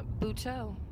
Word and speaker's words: Buteau: Buteau. – Okay. – B-U-T-E-A-U. Yeah. – Oh, okay. – Kind of Frenchy a little bit.